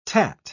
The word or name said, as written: tat